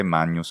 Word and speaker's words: Magnus: GN, si pronuncia, ovviamente, Magnus come a Magna, a Roma, che sta a Magna.